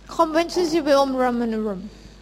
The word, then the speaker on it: Conventus Civium Romanorum: Conventus Civium Romanorum. – Right, there's no V's in Latin, right? They pronounce it like a W, just like Thai, right? – เป็นภาษาละตินนะคะ Right, Conventus Civium Romanorum. – Conventus Civium Romanorum.